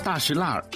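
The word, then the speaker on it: 大栅栏: 大石烂读作大石烂，是融合了少数民族方言的北京土话，原本叫做廊坊四条，是前门外一条著名的商业街。当年老北京有句顺口溜，看玩意儿上天桥，买东西到大石烂。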